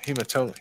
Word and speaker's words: hematoma: He had a hematoma. So how you say it? Hematoma. – Mm-hmm. Hematoma comes from shaking your head too much.